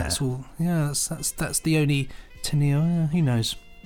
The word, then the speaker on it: Teniel: Well, it's spelt T-E-N-I-E-L, so Teniel is how I'm pronouncing it.